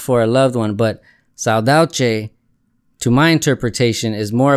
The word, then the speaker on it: saudade: I felt for the first time saudade. Am I saying that right? – Yeah, saudade. – saudade. – Which is a word that only exists in Portuguese.